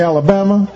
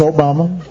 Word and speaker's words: Obama: Although people would mispronounce it, they would call me Alabama, or they'd call me Yo Mama, and I'd explain, no, it's Obama.